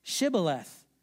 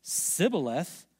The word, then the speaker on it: Shibboleth: And when he said, "No," they said to him, "Say Shibboleth." And when he said, "Sibboleth," for he could not pronounce it right, then they seized him, slaughtered him at the fords of the Jordan.